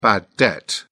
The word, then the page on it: bad debt: British English